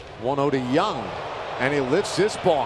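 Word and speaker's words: Jung: That is Josh Young with a J. I think it's a soft J, like yogging.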